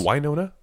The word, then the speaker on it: Winona: Cause she Are you saying Winona? – Winona Ryder. – I've never heard anybody put the accent on the first syllable.